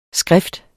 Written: skrift

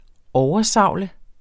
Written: oversavle

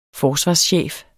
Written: forsvarschef

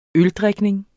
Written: øldrikning

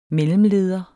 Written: mellemleder

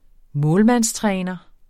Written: målmandstræner

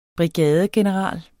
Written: brigadegeneral

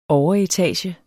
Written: overetage

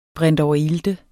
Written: brintoverilte

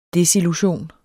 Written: desillusion

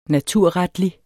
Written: naturretlig

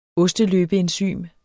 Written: osteløbeenzym

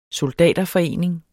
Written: SOLDATERFORENING

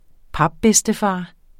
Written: papbedstefar

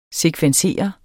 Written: sekvensere

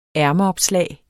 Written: ærmeopslag

